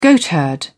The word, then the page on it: goatherd: British English